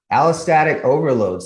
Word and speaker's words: allostatic overload: Yeah, allostatic overload.